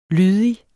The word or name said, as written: lydig